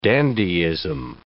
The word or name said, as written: dandyism